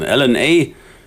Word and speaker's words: LNA: LNA, ausgesprochen wie DNA.